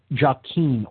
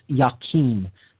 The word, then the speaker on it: Jachin: This pillar is known as the pillar of Jachin, or sometimes pronounced Jachin. That's J-A-C-H-I-N.